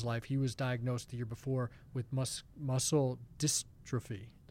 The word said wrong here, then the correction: muscular dystrophy: Muscular dystrophy.